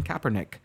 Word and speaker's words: Kaepernick: and when you have Colin Kaepernick, who said, I said it right this time, he said that.